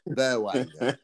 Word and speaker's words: Berwanger: Yes, it is spelled B-E-R-W-A-N-G-E-R. Bear Wagner.